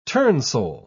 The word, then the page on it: turnsole: British English